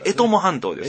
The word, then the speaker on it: 江友半島: はいはい、半島と書いて、江本半島と読みますと。 – 江は読みますね。江本がちょっと読めやすい。あ、ごめんなさい。江友半島です。